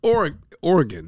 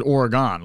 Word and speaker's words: Oregon: I always pronounced it Oregon. Like, I played the Oregon Trail when I was a kid. But is that a Northeast thing? Because everyone Oregon. Out there is like, Oregon. – Because kind of let it roll out. Just Oregon. All right. See, we overpronounce it. Oregon.